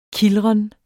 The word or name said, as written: kildren